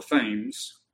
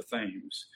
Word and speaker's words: Thames: We call it the Thames. But it's named after a river in England called Thames. They pronounce it Thames. It's spelled the same way. Looks like it'd be Thames, but it's Thames over there, where London is.